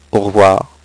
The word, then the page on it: au revoir: French